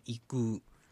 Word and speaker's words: 行く: あと、行くはですね、うをなんか意識して言うといいと思います。行く。